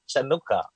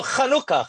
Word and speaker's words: Hanukkah: I think it's spelled Chanukah, right, but it's pronounced Hanukkah or Hanukkah.